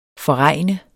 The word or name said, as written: forregne